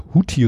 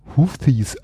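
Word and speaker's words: Houthi: Jetzt habe ich in einem äh, Radiobeitrag gehört, dass die, ich immer huthi rebellen so wurden sie erst ausgesprochen in den Nachrichten, jetzt werden hm. Houthis, also so wie TH ausgesprochen, was ich ein mhm.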